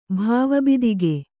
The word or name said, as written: ಭಾವಬಿದಿಗೆ